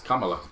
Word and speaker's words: Kamala: I keep calling her Camilla. That's how I hear her name, Camilla. – That's not how she pronounces it, though. – I know. I keep hearing Camilla. Whatever. – That's Kamala.